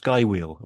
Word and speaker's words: Skwheel: It's spelled SK Wheel, but it's pronounced Skywheel, apparently.